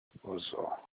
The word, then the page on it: ozo: Olukumi